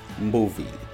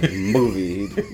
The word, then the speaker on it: Mbuvi: It's spelled M-B-U-V-I, and you pronounce it Mbuvie. – So you barely pronounce the M. – Yeah, but it's like attached to the B, but again, you hardly can, you know, say it. It's like Mbuvie, you know.